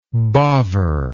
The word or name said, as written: bovver